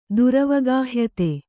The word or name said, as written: ದುರವಗಾಹ್ಯತೆ